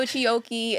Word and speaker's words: Chioke: Yes, so make sure y'all follow Chioke.